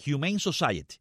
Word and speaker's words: Humane Society: Tremenda acción de esta ONG, Human Society, así se pronuncia, ¿verdad? Y entonces, Humane Society, así es como se pronuncia, muy bien.